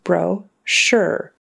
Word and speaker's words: brochure: We do say that O as a long O, bro-sure.